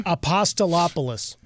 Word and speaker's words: Apostolopoulos: Apostolopoulos? Is that how you pronounce that name? – Apostolopoulos. – I just call him Steve. – Nicely done.